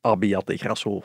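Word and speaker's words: Abbiategrasso: En dan wil uh, Milaan Sanremo in de praktijk wel eens Abbiate Grasso Sanremo worden.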